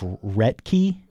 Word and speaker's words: Roethke: His last name is spelled R-O-E-T-H-K-E. And I believe it's something along the lines of Rettke.